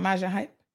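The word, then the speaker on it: Majah Hype: Someone said it's pronounced major without the R. His name is what? Hold on now. Maja, it's not Maja Hype? Major without the R at the end to reflect the Caribbean accent pronunciation.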